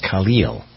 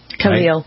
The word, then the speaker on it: Khalil: Khalil Gibran. I never really thought I pronounced his name correctly, but it's Khalil. – Khalil.